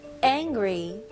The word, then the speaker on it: angry: n 바로 ng. ng 그 발음으로 가셔야 돼요. n 발음이 아니고요. angry. angry. angry. 강세가 어디 들어가죠? 네, angry. 네. 첫 번째 음절에 들어가죠.